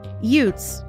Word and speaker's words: Utes: Utes, to be clear, is the local band of indigenous people, not how Vinny Gambini pronounces youths in My Cousin Vinny.